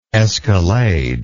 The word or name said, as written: escalader